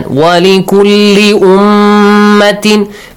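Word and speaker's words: ولكل أمة: Ahsan. Walikulli ummatin. Make sure you pronounce the tashdeed.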